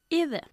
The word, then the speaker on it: either: I don't have a Rolls Royce either. – Muy bien. Either significa tampoco. Y hay dos pronunciaciones aceptables. Either o either. Realmente da igual cuál de las dos utilices.